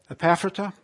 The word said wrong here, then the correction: Ephrata: I believe. Is that right? Come on. Somebody help me. Epaphrata. Yeah? What? Ephrata. Okay.